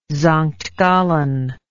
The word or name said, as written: Sankt Gallen